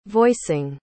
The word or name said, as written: voicing